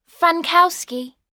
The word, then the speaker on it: Frankowski: Frankowski. – Frankowski. How do you spell it? – F-R-A-N-K-O-W-S.